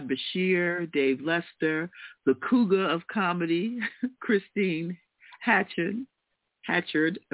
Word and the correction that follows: Hanchard: am I saying it correctly? Hanchard, yeah.